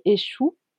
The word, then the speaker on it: Exu: Et donc cette divinité s'appelle Échou. en français, ça s'écrit E X U, Échou.